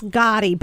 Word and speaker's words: Gati: So it's Nicholas Gotti, but it's spelled with an A.